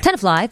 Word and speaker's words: Tenafly: Politico actually obtained the camera footage from an incident last month where police pulled over a car in Tenafly, New Jersey. Hoping I'm saying that right. – Tenafly, thank you.